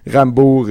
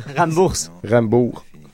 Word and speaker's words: Rambours: Rambourg et Douéki. C'est ça. – Rambourg. Non, eux autres, ils le prononcent le S.